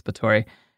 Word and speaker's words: Báthory: She was this, it's spelled Báthory, but it's Báthory.